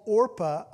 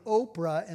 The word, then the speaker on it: Orpah: She was called Orpah on her birth certificate. And through time, people were mispronouncing her name. So they were calling her Oprah instead of Orpah.